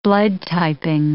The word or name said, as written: blood-typing